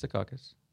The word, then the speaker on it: Secaucus: Is if that's how you pronounce it. – Okay. Yeah, it's Secaucus. Yeah, but it's got news about Leonard Fournette and the, I think this is like Louisiana.